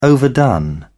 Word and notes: overdone: British English

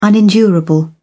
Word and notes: unendurable: British English

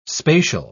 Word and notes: spacial: British English

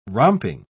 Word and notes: romping: British English

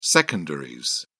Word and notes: secondaries: British English

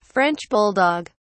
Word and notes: french bulldog: British English

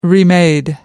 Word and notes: remade: American English

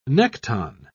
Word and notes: nekton: British English

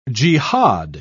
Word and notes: jehad: British English

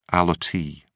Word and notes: allottee: British English

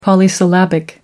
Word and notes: polysyllabic: American English

